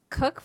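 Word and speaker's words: Cooke: Cook for Wisconsin, C-O-O-K-E. I've got a silent E on my last name, and then F-O-R all spelled out, Wisconsin all spelled out.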